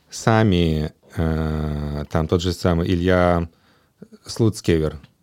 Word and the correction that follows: Суцкевер: Слуцкер, правильно говорю ему Суцкевер. – Суцкевер, да.